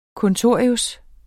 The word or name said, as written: kontorius